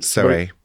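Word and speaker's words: Soay: In the winter months, they ate salted mutton because they have, I don't know how to pronounce it, soe sheep. – Soe. – How do you say it? – Soe sheep, which almost look like goats.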